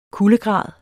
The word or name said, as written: kuldegrad